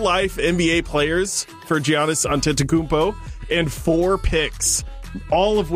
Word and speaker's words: Antetokounmpo: Yes, and Giannis Antetokounmpo. It's Antetokounmpo. – I was close.